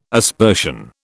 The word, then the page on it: aspersion: British English